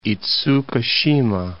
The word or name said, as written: Itsukushima